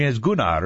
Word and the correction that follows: Gunner: or something like that. Swedish pronunciation. I got berated on that one. – Gunner. Like a gunner, like in a gun.